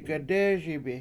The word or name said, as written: gedEESibi